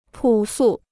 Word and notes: 朴素: pǔ sù

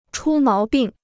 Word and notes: 出毛病: chū máo bìng

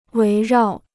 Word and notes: 围绕: wéi rào

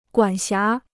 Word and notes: 管辖: guǎn xiá

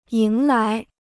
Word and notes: 迎来: yíng lái

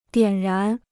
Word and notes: 点燃: diǎn rán

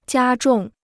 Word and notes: 加重: jiā zhòng

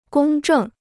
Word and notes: 公正: gōng zhèng

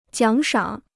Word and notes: 奖赏: jiǎng shǎng

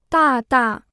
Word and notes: 大大: dà dà

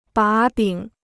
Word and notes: 把柄: bǎ bǐng

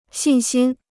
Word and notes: 信心: xìn xīn

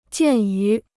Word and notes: 鉴于: jiàn yú

